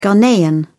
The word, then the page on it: ghanaian: British English